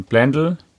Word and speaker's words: Blendle: Du ähm, kommst ja von Blendl hierher. Schön ausgesprochen, ja. Ja, das habe ich mir direkt gemerkt. Ja, es das heißt nämlich Blendl und nicht Blendle.